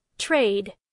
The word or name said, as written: trade